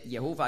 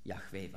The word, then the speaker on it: Jahwe: Ja, darum habe ich ja vorhin gesagt, dass Jehova eigentlich eine falsche Aussprache ist.